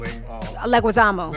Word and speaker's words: Leguizamo: Leguizamo.